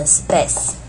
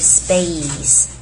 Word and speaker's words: space: 好， for the space and the time， space 也就是跟 Facebook 的 face 是一样的声音，所以请注意不要念成 space，它是 space，注意这个 a's。